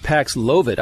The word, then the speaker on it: Paxlovid: I know now it's pronounced Paxlovid. I was calling it Paxlovid, but Jen Psaki said Paxlovid. – That's right. And if she says it, you know it's right. – It must be right. Second syllable.